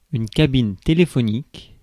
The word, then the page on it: cabine téléphonique: France (Paris); /ka.bin te.le.fɔ.nik/